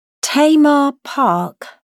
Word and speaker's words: Tamar: Tamar Park? – Tamar is spelt T-A-M-A-R.